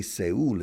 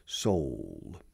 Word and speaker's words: Seoul: Now, remember this, because Spanish people usually pronounce it Seoul, which is the Spanish pronunciation, but it's not the English pronunciation. And if you say Seoul in English, people will not understand what city you're referring to. It's like Sol, como el Sol, la luna y el Sol.